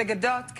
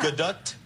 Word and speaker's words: Gadot: The Gadot can be better. – Gadot. – More tea at the end. Yeah. – Gadot? – Yeah. – Yeah. I was steered the wrong way. Gadot.